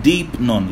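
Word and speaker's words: deipnon (δεῖπνον): Is the word deipnon. It's spelled D-E-I-P-N-O-N. Deipnon is spelled D-E-I-P-N-O-N.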